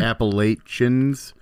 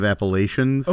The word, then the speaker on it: Appalachians: Hard with the ch instead of a sh. Like okay. Appalachians. – Okay. – Instead of Appalachians.